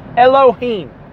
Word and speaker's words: Elohim: Elohim, which is Aleph, Lamed, He, Vav, Mem, or E-L-O-Y-M, or E-L-O-H-Y-M, Y-M, Y-M, I sounded so southern, Elohim, all right? We spell that in English, E-L-O-H-I-M, Elohim.